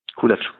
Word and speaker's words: Kulac: hat er gegen den Ulvi Kulak, Kulak? Kulac. Mhm. Kulac, gut, ich, wie das ausgesprochen wird, hat er ja in der Richtung ermittelt.